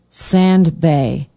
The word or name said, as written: Sand Bay